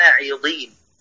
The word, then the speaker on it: عضين: لا، قبلها أقول إني أنا النذير المبين كما أنزلنا على المقتسمين الذين جعلوا القرآن عضين، ضين بكسر الضاد. أي صفة الإطباق في الضاد المكسورة مالها؟ كيف النطق يعني؟ كيف النطق؟ نذكر كم كلمة عيضين